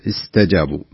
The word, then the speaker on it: استجابوا: الله، في قوله تعالى والذين استجابوا، لعل بسبب التردد اللي حصل لك كانت السين ضعيفة اللي هو حرف الصفير، كأنك يعني السين تجدنك يا الله نطقتها، الذين استجابوا كأنك انطلقت التاء على طول. الصحيح أن يكون أن تكون السين واضحة بحيث أنها حرف صفير. والذين استجابوا استجابوا بهذه الطريقة. الثانية